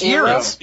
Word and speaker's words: gyro: Actually, gyro. actually, it's the G is silent. – Yeah. – So it's Euro.